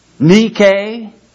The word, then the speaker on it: nike: It is nu iota kappa epsilon, Nike, or we pronounce it Nike.